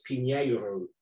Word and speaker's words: Pinheiro: So although I have like 100% Portuguese grandfathers on both sides, and the correct pronunciation of my name, I should say that now, is Pinheiro.